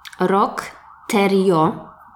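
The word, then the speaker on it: Roch Thériault: Historia jest o mężczyźnie, który nazywa się Rock Terio. Jest to imię francuskie, także specjalnie sprawdziłam, jak się je wymawia, żeby nikt mi tu nie mówił, że źle wymawiam.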